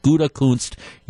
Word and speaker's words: Gutekunst: Don't look at how you spell it. It's pronounced Gouda like the cheese, and then Kunst like the former Packers linebacker George Kunst with an ST on the end. Gutekunst, you will be correct.